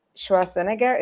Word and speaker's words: Schwarzenegger: Yeah, that's interesting, Sir, I've he- I've heard that they wanted to have Arnold Schwarzenegger. Is that how you pronounce it in English? Yep. Mm-hmm.